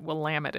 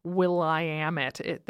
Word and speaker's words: Willamette: Because that's how you know how to say it, Willamette. It's not the Will-I-am-it. There's no second I in it, and so that's how you know it's Willamette.